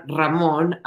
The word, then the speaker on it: Raymond: Ramón Down, y él pronuncia su nombre Ramón, aunque se escribe Raymond, y el apellido lo pronuncia Down, se escribe D-O-A-N-E es eh, nuestro candidato para el Senado.